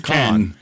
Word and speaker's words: Cannes: Is it Cannes or Cannes? I always thought it was Cannes. – It's Cannes.